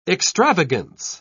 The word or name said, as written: extravagance